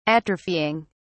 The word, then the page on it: atrophying: British English